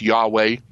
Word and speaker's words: Yahweh: But we also say that, you know, God the Father, particularly as he's revealed in the Old Testament, there's the sacred name, which sometimes is pronounced Yahweh, or as many good Jews would be afraid to say that name out loud because it's respected.